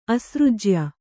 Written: ಅಸೃಜ್ಯ

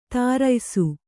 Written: ಧರಿಯಿಸು

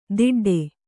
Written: ದಿಡ್ಡೆ

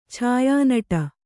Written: ಛಾಯಾನಟ

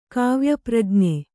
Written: ಕಾವ್ಯಪ್ರಜ್ಞೆ

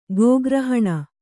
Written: ಗೋಗ್ರಹಣ

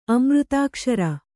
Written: ಅಮೃತಾಕ್ಷರ

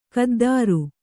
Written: ಖುದ್ದರ

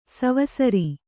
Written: ಸವಸರಿ

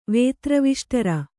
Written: ವೇತ್ರವಿಷ್ಟರ

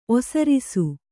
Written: ಒಸರಿಸು

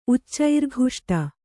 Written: ಉಚ್ಚೈರ್ಘುಷ್ಟ

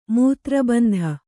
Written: ಮೂತ್ರಬಂಧ

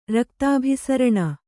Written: ರಕ್ತಾಭಿಸರಣ

